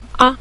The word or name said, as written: a5